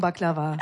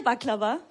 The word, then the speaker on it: Baklava: Wie sagt ihr Baklava? – Baklava. – Und wir sagen Baklava. – Baklava? – Ja, gleiches Wort, da bulgarisch ausgesprochen. – Anders betont, ja.